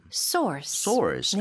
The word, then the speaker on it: source: 이거는 source. 중간에 R 발음 강하게 해주셔야 돼요. – Mm-hmm. – 네, 달라요. Sauce 하고.